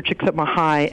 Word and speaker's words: Csikszentmihalyi: And I will have to say, I come by my pronunciation, honestly, I met Dr. Csikszentmihalyi at a conference that he was giving, and I had the opportunity to have lunch with him, and I was sure to pronounce his name correctly.